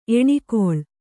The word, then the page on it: ಎಣ್ಗೊಳ: eṇgoḷa